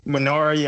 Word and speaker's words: Minari: It's like M-I-N-I-R-I. – Minari? – Minari, yeah.